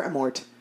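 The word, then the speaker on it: Amorth: Um, is the correct pronunciation is Father Amort, and um, there is this documentary that really does sound fascinating.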